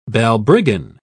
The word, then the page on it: balbriggan: British English